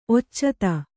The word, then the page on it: ಒಚ್ಚಿತ್ತು: kannada